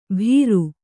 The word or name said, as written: ಭೀರು